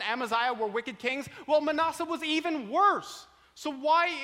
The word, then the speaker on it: Manasseh: Conversely, if we go down to verse 10, it says here, and Hezekiah, the father of Manasseh, and I was corrected on my pronunciation of Manasseh earlier. Ed didn't like the way I was pronouncing Manasseh, so I'll say Manasseh.